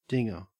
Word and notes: dinger: Australian English

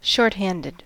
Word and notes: shorthanded: American English

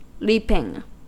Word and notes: leaping: American English

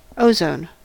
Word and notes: ozone: American English

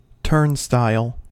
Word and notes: turnstile: American English

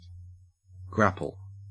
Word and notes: grapple: Australian English

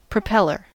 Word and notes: propeller: American English

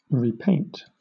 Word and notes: repaint: British English

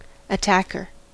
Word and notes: attacker: American English

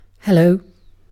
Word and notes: hello: British English

